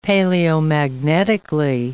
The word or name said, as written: paleomagnetically